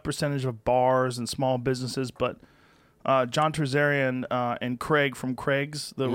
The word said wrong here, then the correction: Terzian: Did I say his name wrong again? Terzian. I said Terzarian.